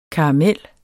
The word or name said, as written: karamel